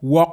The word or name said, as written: Wawk